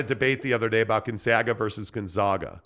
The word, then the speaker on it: Gonzaga: I want you to say it right. It's Gonzaga. I paid for Gonzaga. That degree. And you'll respect my authority. It's Gonzaga. – Gonzaga.